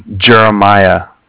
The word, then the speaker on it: Jeremiah: No, Jeremiah. – Can you spell it, sorry? – Sure, yeah, it's J-E-R-E-M-I-A-H.